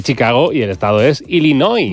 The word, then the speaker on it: Illinois: Se pronuncia Illinois. – Sí, perdón, perdón, sí. – Illinois. – Illinois. Pues yo digo Illinois y no entendemos todos. Porque como diga Illinois, nadie lo entiende.